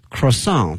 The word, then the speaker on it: croissant: 后面它是一个巧克力哈 ,chocolate croissant。这词呢这个发音大家注意了，这个词应该是一个法语词，所以呢后面这个 T 呢是不发音的啊，把这个拼写给大家说一下。 C-R-O-I-S-S-A-N-T 啊，那么这个词有的时候呢在一些超市啊在一些面包店会看的哈 ,croissant。